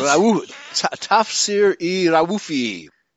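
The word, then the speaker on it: Tafsir-i Raufi: Tafsir I Raufi. In those languages, they pronounce practically every vowel. Okay, it's very rare that they combine vowels like we do in English. You know, like O and O I into a single syllable. So it's Tafsir I Raufi.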